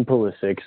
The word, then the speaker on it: Pulisic: Well, I think you got the name right. It is Christian Pulisic, so good job on that one.